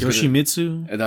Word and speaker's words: Yoshimitsu: Law, I always Yoshimitsu. – That's how you say it.